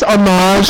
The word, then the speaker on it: homage: It's not a French word. It's homage.